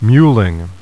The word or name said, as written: mewl